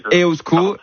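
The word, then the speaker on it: Eusko: Non, mais vous faites bien déjà, vous dites EUSCO, donc on va déjà le prononcer correctement.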